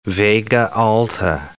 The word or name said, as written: Vega Alta